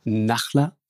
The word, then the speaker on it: Nahlah: Nachler weich ausgesprochen, Salmé, ist bei uns, äh, ihres Zeichens Forensikerin, Frau Salmé.